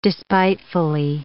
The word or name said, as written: despitefully